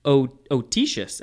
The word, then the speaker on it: autoecious: Autotius. – Let's see. Otitius, actually.